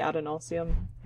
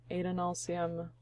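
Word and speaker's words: Adonalsium: I used to say Adenalsium, and I'm pretty sure it's A, like Adolin, Adenalsium.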